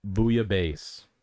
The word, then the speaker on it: bouillabaisse: Bouillabaisse.